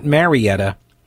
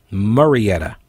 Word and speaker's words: Murrieta: Not Marietta, Murrieta. It's like the male version of Marietta. It's Murrieta. Anyway, it's the police department in Murrieta, California.